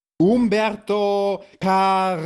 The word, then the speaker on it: Humberto Carrillo: Humberto Carrillo. – Ganz genau, das äh, Doppel-L ist ja ein J.